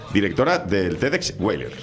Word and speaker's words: TEDx: Y cuando vi la información que me llegó sobre la próxima edición del TEDx, ¿lo he dicho bien? – Perfectamente.